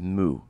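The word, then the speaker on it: MØ: It's M-O, but it's pronounced Moo.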